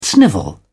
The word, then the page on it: snivel: English; British English